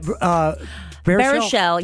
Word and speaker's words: Baruchel: Baruchel.